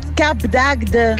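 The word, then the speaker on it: Cap d'Agde: Si pronuncia Cap d'Agd.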